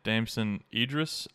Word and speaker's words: Damson Idris: And Damson Idris, I think is how you say his name.